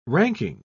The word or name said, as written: ranking